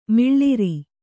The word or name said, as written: ಮಿಳಿರು